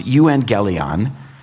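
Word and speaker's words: euangelion (εὐαγγέλιον): Our word evangelism actually just comes from a Greek word. It's pronounced euangelion.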